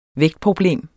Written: vægtproblem

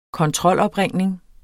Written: kontrolopringning

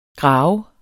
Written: Gravede

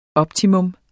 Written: optimum

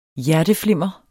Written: hjerteflimmer

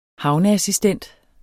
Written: havneassistent

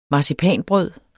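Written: MARCIPANBRØD

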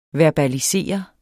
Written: verbalisere

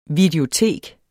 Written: videotek

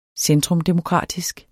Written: centrumdemokratisk

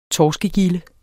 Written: torskegilde

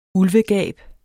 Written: ulvegab

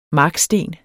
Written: marksten

